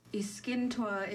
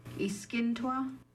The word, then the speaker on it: Iskintwa: Iskintwa is how you say black bear in Kikst. Iskintwa.